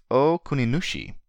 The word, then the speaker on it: Okuninushi: Last episode, I gave the god's name as Okunikushi, which is both incorrect and a mispronunciation of the first letter. The name is Okuninushi, and I will correct that this episode and offer my apologies.